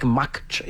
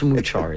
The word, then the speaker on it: Muchar: It looks like Makchar. Yeah, it looks like Makchar. But it's Muchar is how they pronounce it in India.